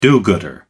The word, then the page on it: do-gooder: American English